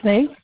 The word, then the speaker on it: Sneed: Tiffany Snee. – Oh, can you spell it for me? – S-N-E-E-D.